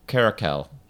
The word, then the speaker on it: caracal: Or how do you say it? – Caracal. – Oh, yeah. – Oh, well.